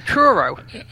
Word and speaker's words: Truro: T-R-U-R-O. Take a shot. Truro? – If it was Cornwall, it would be Truro.